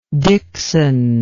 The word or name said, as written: dickson